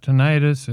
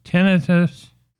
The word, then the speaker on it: tinnitus: I was saying tinnitus, but uh, my favorite rocker was asked how to pronounce it. It's not tinnitus, it is tinnitus.